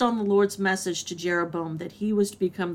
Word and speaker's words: Jeroboam: Jeroboam.